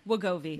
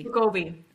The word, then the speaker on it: Wegovy: Wegovy. – Wegovy.